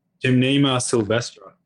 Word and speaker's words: Gymnema sylvestre: Well, the only one I'm actually currently experimenting with is, I'm going to destroy the pronunciation of this word, but it's Gymnema sylvestre. – Gymnema sylvestre.